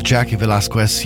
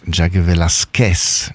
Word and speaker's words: Velasquez: I do speak just a little Spanish, and I know that the correct pronunciation is Jackie Velasquez, not Velasquez.